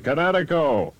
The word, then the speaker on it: Kinetico: Everybody knows that Kinetico is spelled K-I-N-E-T-I-C-O. – I thought the instructor said Connecticut, the East Coast. – Son, you should have remembered kinetic as in kinetic energy with an O on the end. Kinetico. – Kinetico. Spell it K-I-N-E-T-I-C-O.